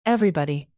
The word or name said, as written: everybody